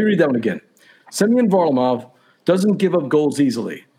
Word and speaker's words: Varlamov: Semyon Varlamov? – Varlamov. – Varlamov.